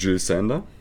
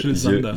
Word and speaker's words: Jill Sander: Oder ich glaub, Jill, Jill, Sander. Jill Sander. Ich glaube, nee, es ist Jill. Äh, nee, Jill. Jill. – Also der Vorname Jill englisch, Sander? – amerikanisch ausgesprochen und der Nachname deutsch.